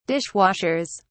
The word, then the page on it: dishwashers: British English